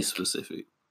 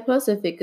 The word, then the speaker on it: specific: Wow, that was like, really specific. – Specific, yeah. Don't say pacific because then I'm gonna be upset.